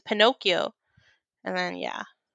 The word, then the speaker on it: Pinocchio: Pinocchio, because she was calling it Pinocho or something like that in Spanish. I'm like, what are you talking about? And I was like, Mom, look, she's not saying it right.